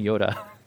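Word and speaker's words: Joda: By the way, in that long list, there's a guy named Yoda. Spelled with a J, J-O-D-A, but it's like pronounced Yoda.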